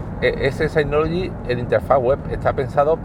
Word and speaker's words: Synology: Se escribe Synology, pero en inglés pronunciado Synology.